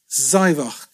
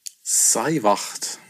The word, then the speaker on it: Sáimheachd: Also da muss man ein bisschen aufpassen, aber geschrieben wird es S-A-I-M-H-E-A-C-H-D. Auf das erste A ist auch ein Akzent drauf. Die Aussprache ist natürlich alles, was man nicht erwarten würde. Seiwach. – Seiwacht. Seiwacht. Sei mit wach. K, K am Ende. Sei, Sei wach.